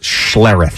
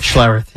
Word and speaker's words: Schlereth: Mark Schlereth. – Schlereth, yeah.